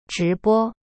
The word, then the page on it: 直播: zhi bo